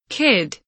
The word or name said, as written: kid